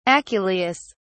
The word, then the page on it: aculeus: British English